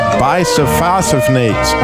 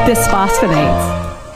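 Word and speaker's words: bisphosphonate: Debbie told me that they overprescribed the use of bisphosphonates. How do you say that? – Bisphosphonates. – Bisphosphonates.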